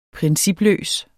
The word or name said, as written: principløs